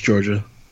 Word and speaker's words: Jorja: Georgia Smith. I don't know. I'm sure I'm not saying. It's like J-O-R-J-A. You know, I think it's pronounced Georgia.